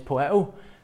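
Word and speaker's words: poieō: I think it's term, the pronunciation is poeo.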